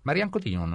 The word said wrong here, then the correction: Marion Cotillard: Marianne Cotillón. – Marion Cotillard. – Marion Cotillard. – Marion Cotillard, estábamos confundidos con la sílaba.